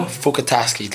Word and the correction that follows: Fatukasi: Did I say it right? – No, that was bad. That was pretty bad. – Fatukasi.